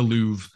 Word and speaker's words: Louvre: Pardon me, what's call it? Lover. It's the lover. – Louver. The Louvre.